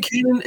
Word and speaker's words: Kanan: It's Kanan, right? – Yeah.